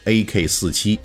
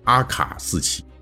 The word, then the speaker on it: AK: 日后影响世界七十年的 AK-47 突击步枪就此诞生了。那这儿插一句哈，就理论上来讲，这个 AK-47 啊，应该读作阿卡四七。不过现在呢，因为都是叫英语发音，大家都念惯了啊，所以这 AK 哎也是可以的。